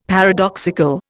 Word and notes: paradoxical: Indian

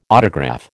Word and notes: autograph: American English

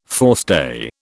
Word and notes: forestay: British English

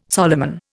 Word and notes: Solomon: Indian

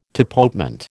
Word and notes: tapotement: American English

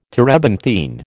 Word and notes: terebinthine: American English

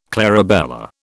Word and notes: clarabella: British English

